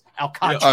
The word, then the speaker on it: Alcantara: Alcantara. I always want to call him Alcantara. It's Alcantara.